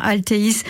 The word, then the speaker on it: Altheys: Ce sont les laboratoires Alteis. Euh, ça s'écrit, je le dis, parce que euh, à cette heure-ci, peut-être pas mal de personnes sont sur un moteur de recherche et l'écrivent pas comme il faut. A-L-T-H-E-Y S.